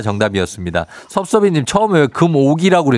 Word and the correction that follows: 금어기: 그랬어요? 아, 예, 걷다가 잘못 발음 나와갖고, 어. 아, 긴장을 해갖고 발음이 잘못 났네요.